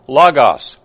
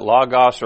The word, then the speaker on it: logos: The word, by the way, the term translated word in the Greek is logos. Now you can pronounce that logos or logos. Either way, both are accepted.